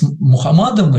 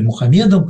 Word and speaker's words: Мухаммад: А если сравнивать его, конечно, ну, с Мухаммадом или Мухаммедом, как по-разному произносится это имя, он тоже пророк, но мы видим, что еще до появления Христа, то есть до появления Мухаммада Христос уже был.